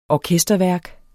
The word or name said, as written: orkesterværk